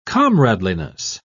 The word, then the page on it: comradeliness: British English